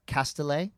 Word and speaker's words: Castellet: I say that right? – Castellet. – Castellet? – Yep. – Oh, because in... Is she from the Basque country? – Yes, hard okay. – tea. – Hard tea. Noted.